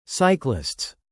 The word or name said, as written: cyclists